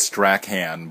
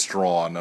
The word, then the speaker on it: Strachan: I had to walk up Strawn, which looks like it's pronounced Strachan, but it's pronounced Strawn,